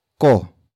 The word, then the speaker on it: ko: After that, we have ko.